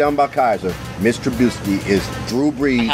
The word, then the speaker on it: Trubisky: I do want to see the Bears game because I enjoy watching Mitch Trubisky. Am I saying his name right? – It sounds good to me.